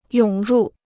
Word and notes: 涌入: yǒng rù

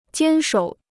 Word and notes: 坚守: jiān shǒu

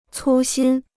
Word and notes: 粗心: cū xīn

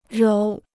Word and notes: 揉: róu

